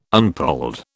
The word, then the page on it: unpolled: British English